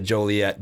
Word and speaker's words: Joliet: And I'm glad that when I said Joliet, that he also, when saying it, said Joliet, because I never knew the right way to say it.